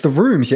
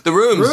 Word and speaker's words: RVMES: It's The Rooms, yeah? – The Rooms. – Rooms. – It's spelled nice and funky, R-V-M-E-S.